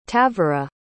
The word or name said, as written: tavora